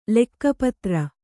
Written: ಲೆಕ್ಕಪತ್ರ

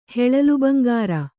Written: ಹೆಳಲುಬಂಗಾರ

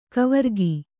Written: ಕವರಗಿ

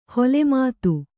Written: ಹೊಲೆಮಾತು

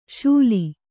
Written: ಶೂಲಿ

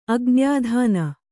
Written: ಅಗ್ನ್ಯಾಧಾನ

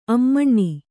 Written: ಆಮೀನ

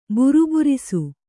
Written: ಬುರುಬುರಿಸು

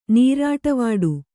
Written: ನೀರಾಟವಾಡು

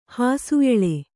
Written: ಹಾಸುಎಳೆ

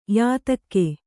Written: ಯಾತಕ್ಕೆ

